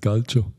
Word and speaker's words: Calcio: Ja, es heißt Calcio.